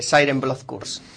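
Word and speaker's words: Siren: Blood Curse: Que antes lo pronuncié yo mal. – Siren Blood Curse.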